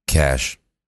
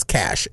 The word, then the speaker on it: cache: C-A-C-H-E. – Really? – I screw this one up all the time. C-A-C-H-E. How do you pronounce C-A-C-H-E? – Cash.